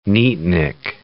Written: neatnik

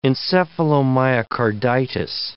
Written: encephalomyocarditis

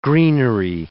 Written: greenery

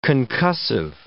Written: concussive

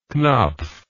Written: Knopf

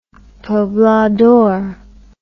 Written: poblador

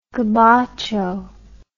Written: gabacho

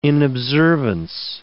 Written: inobservance

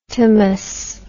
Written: tomous

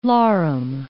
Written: larum

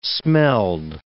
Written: smelled

